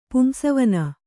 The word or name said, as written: ಪುಂಸವನ